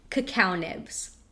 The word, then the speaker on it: cacao nibs: Cacao nibs. – No, that was correct.